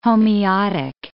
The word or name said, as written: homeotic